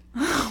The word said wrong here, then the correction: Louis: No, but it's spelled like Louis instead of like Lewis. – Oh. – So I mean, looks like Lois. Okay. But it is Lewis.